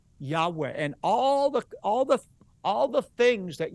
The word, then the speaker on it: Yahweh: Everybody from Moses on refer to him as Yud-Heh-Vav-Heh, and most Semitic scholars believe it's pronounced a breathy Yahweh.